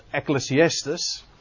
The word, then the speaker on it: Ecclesiastes: Het is interessant dat je in het Engels spreek je de naam van dit boek uit als Ecclesiastes.